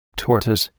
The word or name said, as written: tortoise